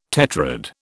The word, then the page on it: tetrad: British English